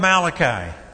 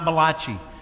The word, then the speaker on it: Malachi: So why does a young pastor go to the book of Malachi? And by the way, that is how it's pronounced. It's not Malachi.